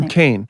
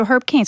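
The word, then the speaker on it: Herb Caen: Herb Cain. – So Herb Cain.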